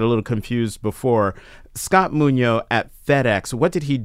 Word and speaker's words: Mugno: How does he pronounce his last name? – M-U-G-N-O. – We'll just say Mugno. I'll do it as Italian.